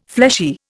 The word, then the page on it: fleshy: Indian